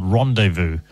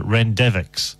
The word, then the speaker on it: Rendezvous: I later discovered it was called Rendezvous. But I was so eager to read books that I didn't really spend any time working on pronunciations because they slowed me down. So I just look at the word, give it my own pronunciation that was good enough for me. So I think I got it Rendevix or something.